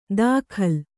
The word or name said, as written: ದಾಖಲ್